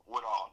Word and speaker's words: Woodall: I want to make sure I'm pronouncing that right. Woodall? – Woodall, that's right. – Woodall, W-O-O-D-A-L-L.